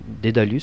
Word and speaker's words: Dédalus: Je pense bien sûr à Claire Bergerot, mais aussi à votre ensemble, puisque vous êtes musicien, guitariste au sein de l'ensemble Dédalus, si je le prononce comme il faut. – Oui, tout à fait.